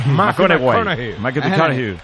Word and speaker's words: McConaughey: Hay que ir corrigiendo la pronunciación. McConaughey.